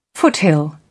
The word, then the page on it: foothill: British English